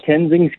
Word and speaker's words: Tenzing: Did I get Tenzing? – Yeah, that's correct. Yeah, Tenzing Skincare.